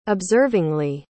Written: observingly

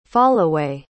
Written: fallaway

